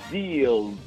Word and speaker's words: Zhizdeals: Of course, I'm talking about these great, great new application called Z Zhizdeals. Let me spell it for you. Z H I Z and deals, D E A L S.